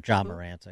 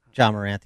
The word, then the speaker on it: Ja Morant: Or Ja Morant, I guess is actually how you pronounce it. Ja Morant, the NBA player.